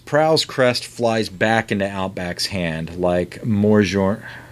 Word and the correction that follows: Mjolnir: I always say that wrong. – Mew Mew. – Like a... – Mjolnir. – Mjolnir, thank you.